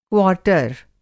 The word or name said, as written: क्वार्टर